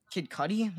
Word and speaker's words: Kid Cudi: Kid Cudi.